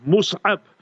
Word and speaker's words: Mus'ab: And in Arabic, how are you going to spell it? Mim, Swad, Ain, Ba, Mus'ab.